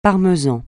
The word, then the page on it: parmesan: francais